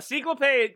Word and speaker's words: Ciclope: Ciclope. – Ciclope.